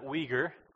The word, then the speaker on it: Uyghur: You pronounce that Uyghur.